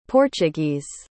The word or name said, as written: portuguese